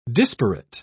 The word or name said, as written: disparate